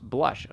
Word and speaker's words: BLVSH: By the way, the name of Blush is actually Blush. It's pronounced Blush, according to the Instagram. – And how is it incorrectly spelled? – B-L-V-S-H.